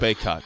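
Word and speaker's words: Bacot: Help me out with the pronunciation of his name. The big guy for North Carolina that came back. – Baycott. Armando Baycott. – Baycott.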